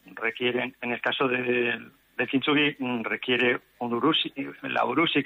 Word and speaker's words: kintsugi: En la conferencia que vas a ofrecer el viernes se hablará del arte japonés del kintsugi, que no sé si se pronuncia así exactamente, sí.